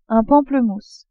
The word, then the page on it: pamplemousse: French